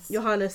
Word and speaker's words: Johannes: Johannes, thank you.